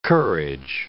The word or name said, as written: courage